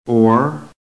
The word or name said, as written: OR